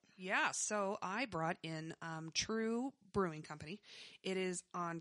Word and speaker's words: TRVE: It's pronounced True. – So it looks like it's spelled T-R-V-E. – Yeah, we used to call it Trive, but that's it's true.